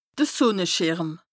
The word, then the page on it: de Sùnneschìrm: Bas-Rhin; Strasbourg